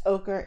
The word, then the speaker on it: ochre: so I actually checked the pronunciation, and yes, ochre is correct.